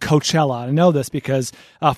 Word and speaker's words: Coachella: He texted me and said, having lived in the Coachella Valley and speaking with old people from Coachella, the A is silent. So even the old-timers there know that it's Coachella.